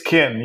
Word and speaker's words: Keen: Did I say that correctly? Keen? How do we pronounce it? – It's Kin, yes. Like kin, like family.